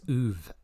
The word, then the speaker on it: Ove: I think it's Ove, actually. – Ooh, was it pronounced? – Yeah, Ove. I saw Tom Hanks was on a YouTube channel that I watched, and he pronounced it Ove. – Well, that makes sense. It's Swedish.